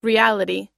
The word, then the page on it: reality: i